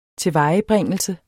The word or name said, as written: tilvejebringelse